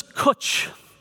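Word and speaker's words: cwtch: I won't ask you to pronounce it, but it's the word is kutch, and it rhymes with butch. So you know how to pronounce this Welsh word.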